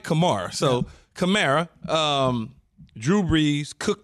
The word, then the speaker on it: Kamara: If they're at full strength, right, given the fact that you have Michael Thomas, you have Emmanuel Sanders, Kamara, if I said it right. – Kamara, Alvin Kamara.